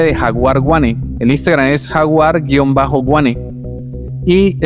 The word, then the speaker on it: Guane: Y Wane es una etnia indígena de aquí en mi región de Colombia. Se escribe G-U-A-N-E.